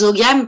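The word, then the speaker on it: Ogham: Alors, je vais dire des ogames parce que je sais que beaucoup de ça s'écrit comme, comme ça.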